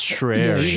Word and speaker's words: Schreier: Schreier was singing its praises on Kotaku the other day. Schreier. Sorry. – I believe it's... No, I think it's pronounced Schreier. – Schreier.